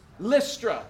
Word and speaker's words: Lystra: Lystra, like Listerine.